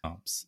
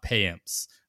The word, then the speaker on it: Payamps: It's Yoel, not Joel. Yoel Piamps, not Payamps.